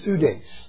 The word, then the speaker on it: pseudes: The word is pronounced in Greek, pseudes. You ever heard the word pseudo?